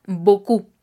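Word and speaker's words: beaucoup: ahí yo les comento que el diptongo EAU, EAU, vamos a pronunciarlo como una O normal, como en español. Y cuando tenemos O-U, este es una U normal en español. Entonces, la P al final no se pronuncia. Por lo tanto, nos queda Boku.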